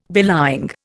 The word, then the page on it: belying: Indian